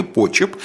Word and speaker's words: Почеп: И Меньшикову были выделены город Почеп, Почеп, прошу прощения, проверил ударение, Почеп, сейчас это Брянская область.